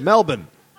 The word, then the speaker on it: Melbourne: Melbourne. – What? – They don't like to pronounce all the letters in their words.